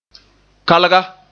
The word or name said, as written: kalga